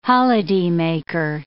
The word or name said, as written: holidaymaker